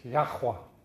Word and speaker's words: Yahweh: And according to the original Hebrew, the Aramaic Hebrew, it's Yahweh. Or if you want to pronounce it properly, it's Yahweh. Yahweh. Y-H-W-H.